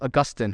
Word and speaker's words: Augustine: Sometimes you read Augustine or Augustine, depending on how you want to pronounce it.